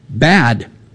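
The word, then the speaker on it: bad (בד): The word linen in the Hebrew is bad. That's exactly how it's pronounced.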